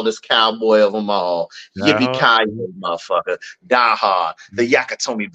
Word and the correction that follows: Nakatomi: Building. The Nakatomi Building.